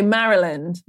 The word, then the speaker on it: Maryland: Okay, Maryland.